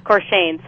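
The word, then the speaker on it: Courchesne: And I wanted to ask particularly about the research of Dr. Cortezny, I believe it's pronounced in... – Corshane, sorry, mm-hmm.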